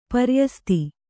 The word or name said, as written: ಪ್ರಯಸ್ತ